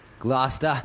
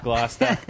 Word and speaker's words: Gloucester: And, And I guess the correct local pronunciation is Gloucester. – Gloucester. – Gloucester.